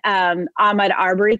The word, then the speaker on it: Ahmaud Arbery: I want to name that in the Aubrey Armory case. I'm sorry, I said that wrong. In Ahmaud Arbery case,